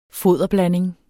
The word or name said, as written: foderblanding